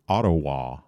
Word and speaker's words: Ottawa: And it's apparently pronounced Ottawa.